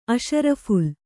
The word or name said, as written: ಅಷರಫು಼ಲ್